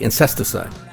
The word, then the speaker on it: Incesticide: Incesticide. – Incesticide, yeah. – It's not insecticide. – Not insecticide. – Incesticide, yes. – Yeah. – They're not killing bugs.